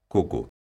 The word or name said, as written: coco